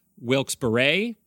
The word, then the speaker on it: Wilkes-Barre: And he says the people who live there pronounce it wrong, and it's actually Wilkes-Barre because one of the gentlemen who founded the city, his last name was Barre, but nobody pronounces it that way.